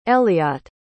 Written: eliott

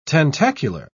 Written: tentacular

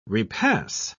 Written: repass

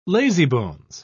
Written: lazybones

